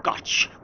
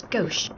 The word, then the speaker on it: gauche: 'Tis pronounced Gotch with the ch sound. – No, Captain, 'tis Gauche. – But 'tis spelled with a ch. – Aye, but the etymology be French, where the ch, when followed by e, be pronounced sh.